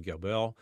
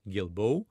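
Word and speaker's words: Guilbeault: And late on Friday, the Heritage Minister, Stephen Gilbeau, I just butchered his last name, Gilbeau, I believe it is,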